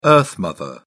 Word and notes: earth mother: British English

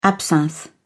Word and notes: absinthe: British English

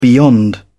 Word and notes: beyond: British English

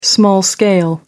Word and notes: small-scale: American English

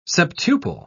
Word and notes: septuple: British English

